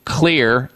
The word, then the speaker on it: Xlear: I would be using Clear, X-L-E-A-R, that's how you spell it, Clear, X-L-E-A-R, their rescue nasal spray.